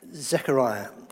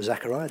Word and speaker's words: Zechariah: In Zechariah, or Zechariah, depending on how you prefer to pronounce it,